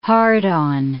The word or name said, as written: hard-on